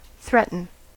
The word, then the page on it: threaten: American English